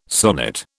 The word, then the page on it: sonnet: British English